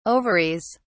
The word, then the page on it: ovaries: British English